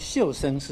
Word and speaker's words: 畜生: 又这个处啊，读音啊，读成这个秀，不读成畜生，也不读成这个畜生，读成秀生是什么意思呢？